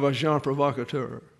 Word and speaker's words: agent provocateur: You have agent provocateur. That's the way you pronounce that.